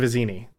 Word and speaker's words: Vizzini: First through Inigo, then Fizzini, or through, Fizzini, and then Vizzino. I think I completely butchered those names. But Fezzik and Vizzini. There we go.